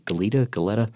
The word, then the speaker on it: Goleta: But Goleta, California, I think is the name of it. I hope I'm pronouncing that right. Goleta.